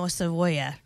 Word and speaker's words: cebolla: No cebolla.